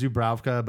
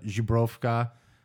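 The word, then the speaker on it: Żubrówka: It's Polish, yeah. But so, you know, us Americans, Zubrowka, but Zubrowka.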